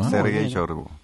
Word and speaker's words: Sergei Shoigu: Voy a tratar de pronunciarlo. Sergei Shoigu. – Qué bien que andas con el ruso. – Andas bien con el ruso. – Sergei Shoigu.